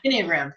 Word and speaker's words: Enneagram: Am I saying that right? Is it Enneagram or Enneagram? – Enneagram.